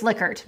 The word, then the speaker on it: Likert: We can't just change the pronunciation of his name when we're talking about the scale he created. It's Likert. Don't be afraid to be different from people just because you're saying it correctly.